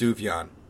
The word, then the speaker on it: Sufjan: Okay, I've always heard it's pronounced Sufjan. – Yeah, that's correct, but I just like Suffgen.